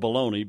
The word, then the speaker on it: baloney: Now, sometimes it's spelled bologna, B-A-L-O-N-E-Y, and pronounced bologna, which is the way I say it.